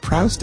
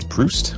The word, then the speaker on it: Proust: Like, it's not Prouse, it's Proust.